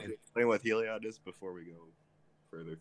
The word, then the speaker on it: Heliod: Heloid. How do I say this? Heloid? – Heliod. – Heliod, okay.